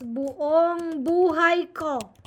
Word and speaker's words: buong buhay: Buong buhay. – Buong buhay. Did I say that right? – Again, say it. – Buong buhay. – Yes, buong buhay ko.